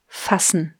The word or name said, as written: fassen